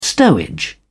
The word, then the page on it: stowage: British English